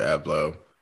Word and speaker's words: Abloh: We all know, like, this past Sunday, Virgil Abloh. This is my question. I pronounced his last name all the way wrong. How do you correctly pronounce it? – You said it right.